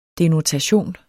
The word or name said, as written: denotation